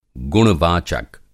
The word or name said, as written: गुण-वाचक